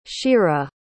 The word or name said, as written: sheera